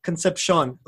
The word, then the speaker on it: Concepcion: Is it Concepcion? Am I saying it right? – The actual pronunciation is Concepcion, like that.